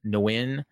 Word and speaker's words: Nguyen: In the other fight that I watched, Ilya Fraimanov got a first round KO over Martin Nguyen, and that's how they pronounced it. So I always pronounce that name Win, but I guess they pronounce the N.